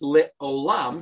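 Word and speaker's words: Lit Olam (לעולם): It could still be pronounced Lit Olam, because you put the vowel is still an O sound.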